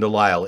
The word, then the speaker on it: Delisle: But Dr. Stephen Delisle, it makes sense now. D-E. Big D, little E, big L, little I-S-L-E. Like an aisle.